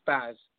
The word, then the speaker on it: Baz: It's Baz. B-A-Z.